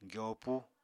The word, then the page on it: gyopu: apatani